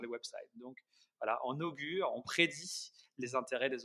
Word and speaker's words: Ogury: Après, le, on l'a un peu twisté au niveau de l'orthographe, ça s'écrit O-G-U-R-Y, parce que c'était pas libre tout simplement dans son écriture originale.